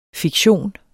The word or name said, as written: fiktion